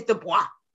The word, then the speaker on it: Du Bois: I'm still not finished with it, but and I'm gonna say his name the way that it is because it's French. Um, but W.E.B. Du Bois. Tired of learning Du Bois. It is Du Bois.